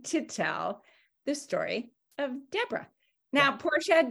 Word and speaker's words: Dibora: Well, and my daughter from Ethiopia she spells it instead of Deborah, spelling it like the way we know, D-E-B-O-R-A-H. Dib is D-I-B-O-R-A. And in Ethiopia, they pronounce it Deborah. And that's their version of Deborah, is Deborah.